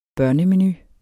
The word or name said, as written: menu